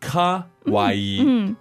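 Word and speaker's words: かわいい: Not to be confused with kawaii. – Ah, it's the same. Their pronunciation is really close. – Really close. – Kawaii, kawaii. – Very close, very close. So we have kawaii, which is cute. And then we have kowai.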